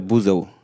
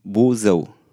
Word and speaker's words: Buzău: Äh, Buzău. Wie? Buzău.